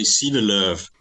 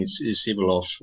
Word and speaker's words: Sivelöv: Yeah, in Swedish we say Sivelöv. But I'm used to having Sivelöv or something like this in outside Sweden.